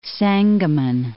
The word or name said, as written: Sangamon